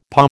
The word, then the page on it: paw: American English